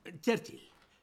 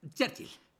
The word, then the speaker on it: Churchill: Churchill. Se dice así. Todo junto. Churchill.